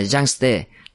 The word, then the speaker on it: Yangtze: "Đó cũng là một trong 11 loại men do Đường Anh sáng chế, tên gọi là Dương Tử, tiếng quan thoại đọc là Yangtze.